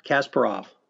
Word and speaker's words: Kasparov: Kasparov.